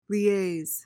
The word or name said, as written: liaise